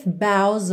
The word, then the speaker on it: boughs: Deck the halls with boughs of holly. Boughs também é uma palavra que tem uma pronúncia um pouco tricky. B-O-U-G-H-S. Boughs, que significam os ramos, ok?